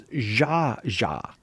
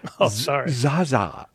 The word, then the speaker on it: Zsa Zsa: It's Zsa Zsa. You said Zsa Zsa.